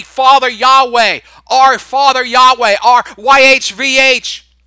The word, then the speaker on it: Yahweh: That's how they pronounced it, was Yahweh.